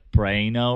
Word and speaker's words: Praino: No, I mean, I think it's technically got a little bit more Italian, like Praino or something like that.